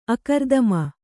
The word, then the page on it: ಆಕೃತಿಮಾ: ākřtimā